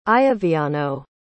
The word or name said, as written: ioviano